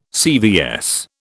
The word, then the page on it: CVS: British English